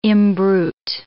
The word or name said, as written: imbrute